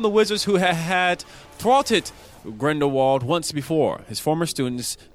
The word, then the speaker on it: Grindelwald: Grindelwald. Did I say that right? – Grindelwald has made his dramatic escape and has been gathering more followers for his cause.